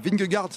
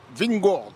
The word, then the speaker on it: Vingegaard: Et maillot jaune ce soir pour le Danois Jonas Vingegaard, ça c'est la prononciation française. Et en danois on dit Vingord.